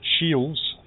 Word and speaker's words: Shiels: No, it's Shiles. S-H-I-E-L-S. Shiles. – Okay. – Yeah, there's no D in it.